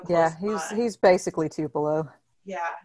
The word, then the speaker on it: Tupelo: Um, so the the movie was shot um, in Tupelo.